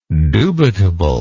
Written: dubitably

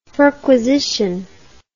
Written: perquisition

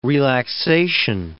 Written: relaxation

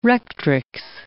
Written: rectrix

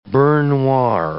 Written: beurre noir

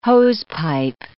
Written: hosepipe

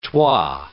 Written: twae

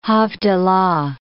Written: habdalah

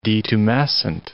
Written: detumescent

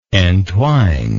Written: entwinement

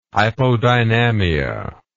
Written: hypodynamia

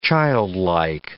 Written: childlike

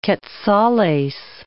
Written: quetzales

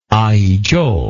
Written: Ahidjo

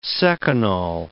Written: Seconal

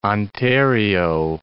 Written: Ontario

